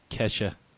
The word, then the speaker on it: Kesha: Think of like a Keisha concert. – Oh, okay. – Or maybe Lady Gaga. Very crowded. – I think it's pronounced Kesha.